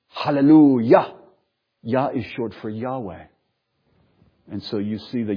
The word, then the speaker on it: Yahweh: So that's part of the reason why we believe that the four consonants, the sacred tetragrammaton, is pronounced Yahweh.